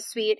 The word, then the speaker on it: Suite: But Sweet is spelled S-U-I-T-E, and it's intended to just interview women in the C-suite, primarily in tech, but all different roles.